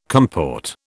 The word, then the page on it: comport: British English